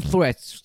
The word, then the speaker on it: threads: 好，就就就这样子，呃，其他的呢就太严肃了，我就不想讲了，可以去我的 threads，threads，t h r e a d s，到底谁不会念 threads 这么好念？为什么还要在那边？还要这边教学，要请外国人拍 short，然后来来教你怎么念，就线呢？是台湾人是不会念这个音吗？就是把舌头放在两个牙齿中间，就念 threats。